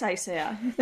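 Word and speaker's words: Haizea: que supongo que por es un nombre vasco, entonces es, la H es muda, la Z se pronuncia como una S, es Aisea.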